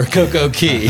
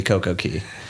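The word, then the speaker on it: CocoCay: The question I have is, how do you say a perfect day at Coco K or Coco Key? – I say Coco K because it rhymes better, but I guess the proper way would be Coco Key.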